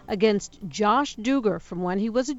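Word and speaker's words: Duggar: Josh Dugar, and apparently that's how you pronounce his name.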